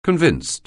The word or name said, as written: convinced